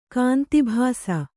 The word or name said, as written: ಕಾಂತಿಭಾಸ